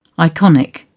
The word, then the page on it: iconic: British English